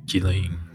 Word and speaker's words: Ghislaine: Jilin. Like almost, you don't pronounce the A, you don't kind of go ing, I-N-E. So the S and A probably are silent.